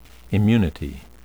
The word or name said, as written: immunity